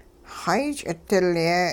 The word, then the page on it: xay-ch'idilye: hupa